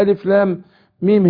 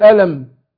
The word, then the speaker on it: الم: لأن لو قرأتها على حسب الكتابة أقول ألم، مش هينفع.